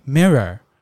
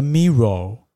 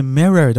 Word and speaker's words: mirror: 因为镜子这个单字 mirror，很多人会台湾人啦，很多人会念成 mirror，因为它有个 o 在那边，对不对？那如果你没有透过 echo method 仔细的听到这个发音是 mirror 的话呢，你觉得重复的念错，念成 mirror，OK？